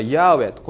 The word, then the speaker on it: ヤーウェ: もともとヘブライ語ではこれはヤーウェ、ここに YHWH と書いてありますが、ヤーウェという言い方をしてるんですけれども、